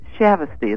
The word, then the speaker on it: Shavesty: And I said, Shavesty. And they said, oh, Shavesty, travesty. Shavesty rhymes with travesty.